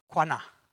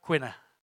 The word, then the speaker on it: qanna: The word in that text is pronounced quana. Not quina, quana. Quinny's not here. Q-A-N-N-A, meaning zealous.